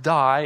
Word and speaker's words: dei: The Greek verb for must is the word die. It's spelled D-E-I.